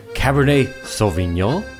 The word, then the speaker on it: Cabernet Sauvignon: Folks, today we're talking about Cabernet Sauvignon. Am I saying that right, Tim? – Oui.